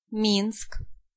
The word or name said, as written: Minsk